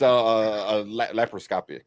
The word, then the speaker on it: laparoscopic: Now I keep wanting to say microscopic, right. But I know it's not. It's laparoscopic.